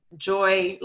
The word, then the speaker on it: Joy: Joy, like the emotion, and panic, also like the emotion.